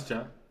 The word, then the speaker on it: Jaa: No, it's Jaa. It's pronounced Jaa.